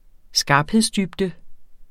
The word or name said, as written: skarphedsdybde